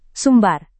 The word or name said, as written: sumbar